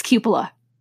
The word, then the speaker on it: cupola: At first I was like, cupola? You know, whatever. – Cupola. – Oh God, it's cupola.